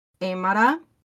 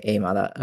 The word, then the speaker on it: Aymara: Okay, I think that one is pronounced Aymara. – Okay, Aymara.